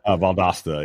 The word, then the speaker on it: Valdosta: Am I saying that right? Valdosta? – Valdosta, yeah. Valdosta. So